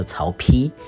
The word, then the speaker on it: 曹丕: 那么以及最后呢，成为皇帝的叫曹丕啊，就是一个步啊，不是的那个步，下面一个一，就是念成丕，丕就是大的意思哈。